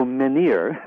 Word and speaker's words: menhir: So menhir, oh. – The French pronunciation, yes.